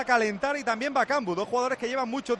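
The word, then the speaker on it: Bakambu: Es que se dice Bacambu.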